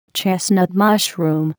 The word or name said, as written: chestnut mushroom